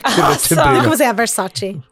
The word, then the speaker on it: Versace: Which Italian word do you wish English speakers would stop saying incorrectly? – Versace. Is Versace? Versace.